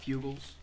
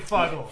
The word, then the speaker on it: Fuggle: We've got Fugles. Fugles is a... – Called it Fugles? – Yeah, I believe... – definitely Fuggle. – For the listeners at home, I believe it's called pronounced Fuggle, as in...